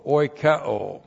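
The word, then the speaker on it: oikeo (οἰκέω): O-I-K-E, long O, oikeo.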